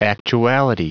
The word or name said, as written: actuality